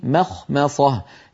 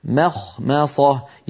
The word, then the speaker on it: مخمصة: ما مخمصة يعني القارئ ينطق ميم مرققة خاء مفخمة ميم مرققة صاد مفخمة، الأمر فيه تناوب وبحاجة إلى يعني تمرين وتدريب، لكنه بعد التدريب يأتي صحيحا. هكذا في مخمصة في مخمصة وليس مخمصة.